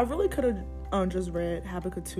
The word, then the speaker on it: Habakkuk: That's a good question, Nia, because the answer is actually given in Habakkuk. Did I say that right? – Mm-hmm. – Habakkuk 2,